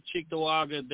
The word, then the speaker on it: Cheektowaga: You've got to pronounce it correctly. It's Cheektowaga. – Cheektowaga? – Cheektowaga.